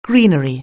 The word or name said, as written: greenery